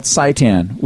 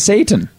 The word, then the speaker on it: seitan: Well, I'd make them out of something called seitan, which people accidentally miss... it's S-E-I-T-A-N. – Satan? – They mispronounce it as Satan.